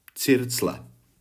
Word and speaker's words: circle: A zrovna tak ve slově circle vidíme I, ale není tam I.